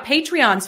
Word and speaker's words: Patreon: And that'll be on Patreon, right? Am I saying that right? – Yeah, Patreon.